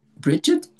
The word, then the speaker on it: Brigid: Sometimes Brigid pronounced with the D at the end or the T at the end. So Brigid is a Celtic goddess of fire, inspiration.